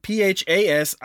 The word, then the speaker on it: Phasianidae: But they belong to the bird family known as Phazen Niande. I butchered it. It's like Phasianidae.